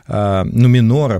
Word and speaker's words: Нуменор: Неоконченные предания Нумерона и Нуменора, прошу прощения. Вот я сегодня вообще буду заговариваться и сразу прошу прощения. Итак, неоконченные предания Нуменора и Средиземья и прочие приквелы.